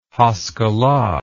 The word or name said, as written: haskalah